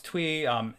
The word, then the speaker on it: Thuy: I believe that's how they pronounce her name. I watched several interviews, so I believe it's spelled T-H-E-Y, pronounced Thuy.